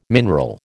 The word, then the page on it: mineral: American English